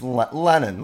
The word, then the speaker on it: Lennon: And so you called her Lennon. – No, it's Lennon, like John Lennon. – Lennon. Isn't it? – Lennon. L-E-N-N-O-N.